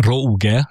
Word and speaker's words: rouge: A rouge francia eredetű szó, a rouge, leírva rouge szó átvétele francia kiejtés szerint.